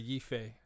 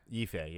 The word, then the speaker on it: Yifei: I guess you would say her name is Iffy Liu, Yeffy Liu, something like that, as Mulan. So yeah, she's new, I think. It's probably Yifei. – Yifei, yeah.